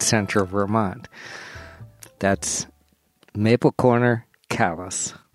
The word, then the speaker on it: Calais: Vermont is not France. Montpelier is not Montpellier. And Calais is not Calais. – It is Calais, Calais, not Calais, or some other French pronunciation. And I know because I live here.